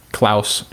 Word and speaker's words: Klaus: It was founded on the 24th of January in 1971 by a German engineer and economist, Klaus Schwab. And I think I said that correctly. Did I say Klaus correct? – Yes, but it's economist.